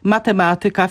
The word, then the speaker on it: matematyka: Mówimy oczywiście matematyka, fizyka, gramatyka, czyli akcentujemy pewne wyrazy na trzeciej sylabie od końca, bo to są wyrazy pochodzenia łacińskiego.